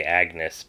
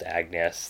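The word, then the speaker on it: Agnès: I want to say Agnes, but it's actually pronounced Agnes.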